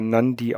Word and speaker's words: Nadi: Das wird Nadi geschrieben, aber Nandi ausgesprochen.